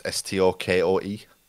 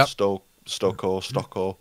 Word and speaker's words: Stokoe: So her and Mr. Matt Stokoe, apologies if I'm pronouncing his name wrong. It's Stokoe. – Yeah. – Stokoe.